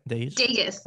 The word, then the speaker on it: Dagus: Dagus, like the Dagus. City Vegas.